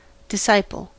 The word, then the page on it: disciple: American English